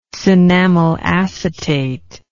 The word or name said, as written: cinnamyl acetate